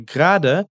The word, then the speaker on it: gerade: A palavra gerada, ou muitas vezes pronunciada na língua mais coloquial como grada, significa agora, ou seja, estou vindo agora, cheguei agora.